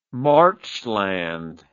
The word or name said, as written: marchland